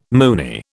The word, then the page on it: moony: British English